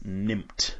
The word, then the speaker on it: Nimmt: My number one filler game is called Six Nimmt by Wolfgang Kramer. It's spelled the number six. N-I-M-M-T.